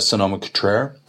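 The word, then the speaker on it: Sonoma-Cutrer: We also had a Sonoma Cutrer.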